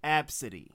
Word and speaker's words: ABCDE: She named her child ABCDE, pronounced Absidy.